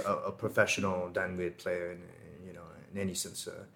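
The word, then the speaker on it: đàn nguyệt: You play the Dan Nguyen, right? – Yeah. – I don't know if I'm saying that right. It's probably not. – No, that's right. – It's Dan Nguyen. – Yeah. Dan Nguyen.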